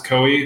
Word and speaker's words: Koei: Samurai Warriors, made by the same company, which I believe it's pronounced Koei. It's K-O-E-I.